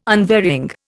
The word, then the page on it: unvarying: Indian